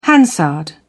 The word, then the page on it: hansard: British English